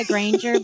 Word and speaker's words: Granger: We go by Granger, but since Toujours is French our name actually used to be pronounced French, so we just, you know, lay it on thick.